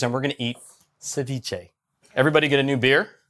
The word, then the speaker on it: ceviche: And on that note, we need to take a break because the Cervace that Ollie made, did I say that right, Ollie? – How do you say it, Ollie? Ceviche.